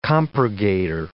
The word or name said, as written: compurgator